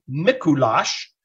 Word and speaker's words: Mikulás: But in Hungary, what happens on the night of December 5th, the night before St. Nicholas Day, or in Hungarian, Mikulás,